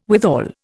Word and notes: withal: Indian

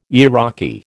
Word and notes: Iraqi: American English

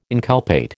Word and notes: inculpate: American English

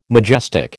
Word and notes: majestic: American English

